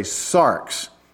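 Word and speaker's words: sarx (σάρξ): Whenever Paul talked about the flesh, most of the time when he talked about this theological, spiritual concept called the flesh, he used a Greek word pronounced this way, sarx.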